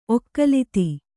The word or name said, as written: ಒಕ್ಕಲಿತಿ